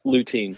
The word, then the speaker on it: lutein: Am I saying that right? Yes. Is it lutein? Yeah. – Yes, lutein. Yeah.